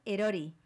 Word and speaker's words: Erori: Y vamos a escucharla una canción que se llama Etori. Bueno, creo que se pronuncia así, porque después pone Erori. ¿Cómo? – Es Ízaro y la canción es Erori.